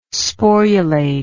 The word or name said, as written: sporulate